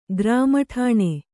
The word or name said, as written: ಗ್ರಾಮಠಾಣೆ